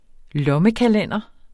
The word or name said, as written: lommekalender